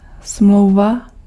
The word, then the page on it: contrat: [kɔ̃.tʁɑ]; /kɔ̃.tʁa/